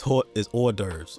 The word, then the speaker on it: hors d'oeuvres: I said that wrong, my bad. – Oh shit, I thought that's how he actually said it. – No, it's hors d'oeuvres. Gotcha. I said hors d'oeuvres.